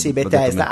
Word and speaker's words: Bethesda: quindi Bethesda, ho detto bene? Sì, Bethesda, anche se in realtà The Outer Worlds è stato sviluppato da Obsidian, che sono appunto gli sviluppatori originali.